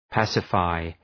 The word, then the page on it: pacify: {‘pæsə,faı}